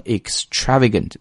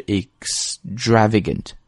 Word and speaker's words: extravagant: 然后在这儿还要再提一个发音 ,extravagant, 而不要读成 extravagant, 为什么呢？虽然你看这个地方的字母是一个 X, 但是它的结尾音是 S, 所以是 extravagant, extravagant outfits, 有连读，然后 outfits 的 it 是一个短音。